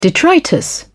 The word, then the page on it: detritus: British English